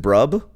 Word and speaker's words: BRB: You know, burb. – Is it burb? – Sure. – Is it brub? – Burb. – Burb. – That makes sense.